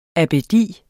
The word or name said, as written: abbedi